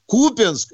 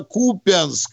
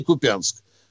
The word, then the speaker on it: Купянск: Но в данном случае сказали Купенск, и э, он родился Купенск, и таким, наверное, и будет всегда, но не Купенск.